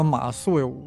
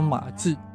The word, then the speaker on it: 马谡: 呀，这个马谡，我记得我小的时候老爱读成马季，我现在都特别担心我给说错。